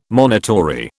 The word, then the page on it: monitory: British English